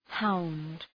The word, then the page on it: hound: haʋnd